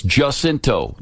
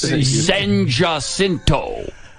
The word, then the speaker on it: San Jacinto: It's Jacinto. This is Houston, Texas. They don't pronounce it the Spanish way. Okay? And they do that on purpose. San Jacinto.